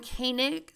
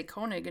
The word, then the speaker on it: Coenig: So that's Brian Koenig, C-O-E-N-I-G. And I'm really glad I asked him how to pronounce that, because I was just going to say Koenig and just go with it. But it's Koenig.